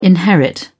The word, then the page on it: inherit: British English